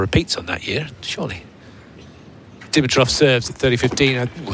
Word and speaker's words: Dimitrov: Dimitrov is pronounced with emphasis on the second syllable.